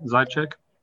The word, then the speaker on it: Zajac: Yep, Zajac. – Zajac. Okay. If you tried to say it like it's spelled, that would be very difficult.